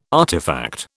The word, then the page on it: artifact: British English